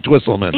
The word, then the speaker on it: Twisselman: Now, did I pronounce it correctly, Twisselman? – You did.